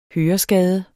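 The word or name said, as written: høreskade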